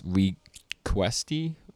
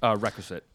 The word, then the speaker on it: requisite: And he goes on, he's like, "Of course, there are the requesty patterns." Maybe I'm saying it wrong. – Requisite. – Requisite patterns.